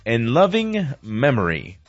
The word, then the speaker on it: in loving memory: ¿A pronunciación aproximada? In loving memory.